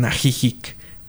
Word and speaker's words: Ajijic: Hay un, un pueblo llamado Ajijic. Eh, ahora lo pronuncian Ajijic.